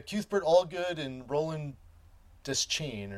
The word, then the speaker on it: Deschain: So instead of it just being his father was dressed in black jeans and a blue work shirt, Stephen Duchesne. So we've got, in the audio, he pronounces Deschain. – Deschain. Oh, I was, I never put the accent that way. I always say just Deschain.